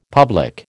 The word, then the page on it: public: American English